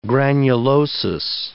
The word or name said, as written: granulosis